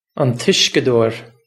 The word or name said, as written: an t Uisceadóir